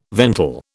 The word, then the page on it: ventil: British English